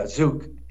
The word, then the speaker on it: Zouk: First of all, the nightclub, the Zouk Group. Am I saying it right? – Yeah, Zouk.